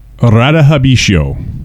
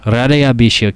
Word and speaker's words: ratihabitio: Ratihabitio. – Ratihabitio. Can you spell that for us? – That's R-A-T-I-H-A-B-I-T-I-O.